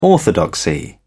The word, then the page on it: orthodoxy: British English